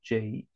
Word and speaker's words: Juwes: And it's pronounced just like Jews, Jews.